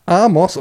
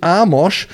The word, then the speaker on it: Amos: Emsiger Selke, moin Strömer, kannst du bitte den guten Knipser, Betonung liegt auf gut, davon in Kenntnis setzen, dass Amos Pieper Amos und nicht Amosch sowie Nabi Keita Nabi und nicht Nabi Keita ausgesprochen werden.